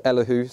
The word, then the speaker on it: Elihu: But there's one guy called Elihu. I believe that's the pronunciation. I did that thing of looking it up on Google and somebody told me it was Elihu. So we'll go with that.